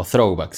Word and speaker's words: throwback: se llama throwback, ¿ya? O throwback, si lo quieren pronunciar bien en inglés.